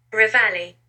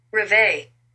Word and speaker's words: Reveille: There's two different pronunciations. This is British. – Reveille. – Reveille? This is American. – Reveille.